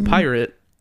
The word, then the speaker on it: PYRT: Pyre Festival, spelled PYRT, but pronounced pirate, is a festival created by the same person who made Fyre Festival.